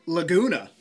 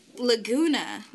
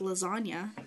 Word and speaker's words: Laguna: The Renault Laguna. – Laguna. That's. I was going to pronounce it like lasagna.